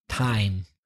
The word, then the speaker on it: thyme: I'm talking about T-H-Y-M-E, time. The herb, which is tomillo. – Tomillo. – T-H-Y-M-E is pronounced time.